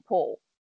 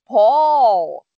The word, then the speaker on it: Paul: And he said, like, oh, Paul. Like wall, you know?